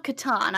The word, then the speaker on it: Catan: And oh, by the way, how do you say that? The Settlers of Catan. I'm right there with you. I pronounce it the same way.